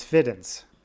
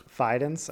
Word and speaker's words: Fidens: I, I would read that as Fiddens. Pretty sure it's Fidens. Fidens, okay.